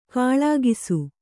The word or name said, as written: ಕಾಳಾಗಿಸು